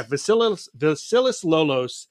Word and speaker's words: Vasilis Lolos: The artist's name is, how would you say that? Vasilis? – Vasilis Lolos? – Yeah, Vasilis Lolos.